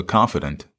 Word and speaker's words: confidant: But even in that place, he found a confidant. – Yeah. – Is that the right word? – Confidant. – A confidant. Excuse me. Wrong wrong emphasis on the wrong syllable.